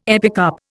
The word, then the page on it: epicarp: Indian